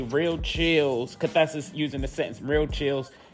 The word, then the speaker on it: cathexis: I'm sorry, cathesis. Cathesis is spelled C-A-T-H-E-X-I-S.